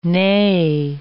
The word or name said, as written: nee